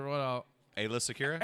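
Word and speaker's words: Aayla Secura: So I had him and Alia. What's her? – Ayla Secura. – Ayla. I always mispronounce her name.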